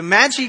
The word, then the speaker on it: Magi: There's no ja sound in that language. G, G. Do it like that. Everybody with me. G. Very good. Okay. So the Magi came and they announced Jesus to be king.